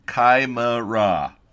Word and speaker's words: Chimera: For everybody in the listener lounge bagging on me for mispronunciation, Chimera, as Hellenix decided to point out to me. Chimera. All right, there you go.